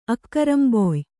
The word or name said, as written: ಅಕ್ಕರಂಬೊಯ್